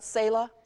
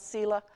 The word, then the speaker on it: Selah: Some pronounce it Selah, some Selah.